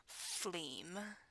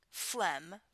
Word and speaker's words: phlegm: Phleam. Phlegm. Phlegm. Phlegm. Because, yeah, because down in Alabama, usually we pronounce it as phleam.